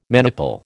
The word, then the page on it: maniple: American English